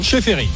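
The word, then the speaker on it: Čeferin: Seferin, le patron de l'UEFA, l'instance européenne du foot.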